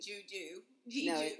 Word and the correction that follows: Jeju: No, it's not juju. That's from Star Wars. That's the Southern version. Say the pronouncing it because you just made it sound like a whole nother location. – Jeju.